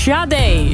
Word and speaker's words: Sade: Jij had het over Sade, maar die naam spreek je uit als Sade.